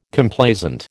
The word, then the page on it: Complaisant: American English